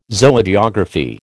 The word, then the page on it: Zoogeography: American English